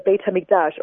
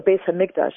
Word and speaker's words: Beit HaMikdash: which was focused on the Beit HaMikdash, or Beis HaMikdash. And because I saw that you go both Ashkenazi and Sephardic pronunciation, so I'll take your lead, Miriam.